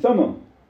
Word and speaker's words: Thummim: they employed the use of Urim and Thummim, are the correct pronunciation, Urim mm-hmm. and Thummim.